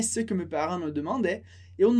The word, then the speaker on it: demandaient: Les parents demandaient, mas a gente termina o som no E, sem pronunciar o ENT.